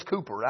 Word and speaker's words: Cowper: I found a book written by William Cowper. I looked it up to see, and according to the computer, the proper pronunciation is Cooper. I knew some people called him Cooper.